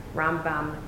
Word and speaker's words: Rambam: But they have the accents on different syllables. So Rambam is always the accent on the first syllable, and Ramban, Nachmanides, is always the accent on the second syllable.